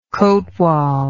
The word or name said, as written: cotwal